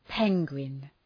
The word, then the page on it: penguin: ‘peŋwın